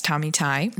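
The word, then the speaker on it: Tommy Tighe: That's Tommy Tai. The GH is silent.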